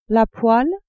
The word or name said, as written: poêle